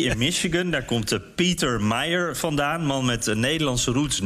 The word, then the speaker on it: Meijer: Ja, hier moest ik a guy who spells his name M-E-I-J-E-R, but they pronounce it Meyer. The hell kind of a spelling is that? Meyer. Meijer. It's Meijer, but it's actually pronounced Meyer. I said, how the hell do you pronounce this guy's name? Nobody knows him. He's done nothing in Washington. I said, how do you pronounce his name? Is it Meijer? Meijer? They said, it's Meyer. How the hell do you get Meyer out of it?